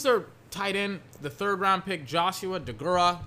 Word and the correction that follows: Deguara: DeGuara. Apparently that's how you say his name.